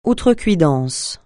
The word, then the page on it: outrecuidance: francais